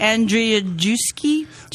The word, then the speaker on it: Andrzejewski: Andrzejewski.